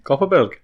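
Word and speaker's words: Kopparberg: You said it wrong the first day, I think. – Copperberg. – Yeah, you said Cockerberg first.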